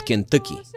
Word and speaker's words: Кентъки: Народа го увековечил в песента «Ловците от Кентъки». Слушате тази песен вече минута и нещо. Усещате, нали, уважаеми слушатели, че правилното произношение всъщност е Кентъки.